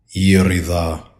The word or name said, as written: IRIDA